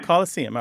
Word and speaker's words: Coliseum: Z-A-M? – There aren't many anglicized or nativized words that end with S-E-U-M. The only one I can think of is C-O-L-I-S-E-U-M. How would you say that? – The Colosseum. – Colosseum. All right. So you wouldn't, you would pronounce the N like most people say museum.